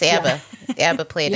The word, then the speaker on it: ABBA: And you know, we were corrected yesterday; they're actually called ABBA. Here in the Midwest, we always call them ABBA. That's three syllables. ABBA.